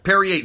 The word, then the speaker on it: Perrier: He's dressed as a bottle of Perrier. Am I saying that right? – I think so, yeah. – Perrier, the green one.